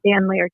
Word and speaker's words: Dan Laird: Am I saying that right? Laird, my fault. – Dan Laird, yes.